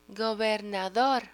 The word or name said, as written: gobernador